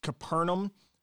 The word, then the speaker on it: Capernaum: The Jewish in the Hebrew would be Capernaum. But, you know, we hear it as Capernaum. We say it as Capernaum. So I'm going to pronounce it as Capernaum. If you watch The Chosen as well, it's Capernaum on there too. But it's interesting how, like, the Hebrew pronunciation would be Capernaum, but we pronounce it Capernaum.